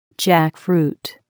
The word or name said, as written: jackfruit